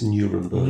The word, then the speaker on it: Nuremberg: If you're going to anglicize, it's Nuremberg.